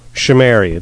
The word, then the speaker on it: Chimeri: Chimeri. It's an SH sound, but a CH spelling.